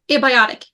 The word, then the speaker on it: abiotic: And how do you pronounce this? Abiotic issues, is that right? – Yeah, abiotic, yep.